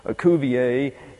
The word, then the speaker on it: Cuvier: And Cuvier is his correct pronunciation. Cuvier is the anglicized pronunciation.